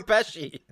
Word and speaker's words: Pesci: He's supposed like Joe Pesci. – No, that's how you pronounce Pesci, right? – Yeah. – Joe Pesci? – Yeah, I was just trying.